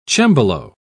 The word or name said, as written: cembalo